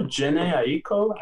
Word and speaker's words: Jhené Aiko: And then also Jhené Aiko, I think I said that name right, if I didn't. – Dude, that's how I have it. I spelled it phonetically in my notes, and that is how I have it. So I think you're on the right track.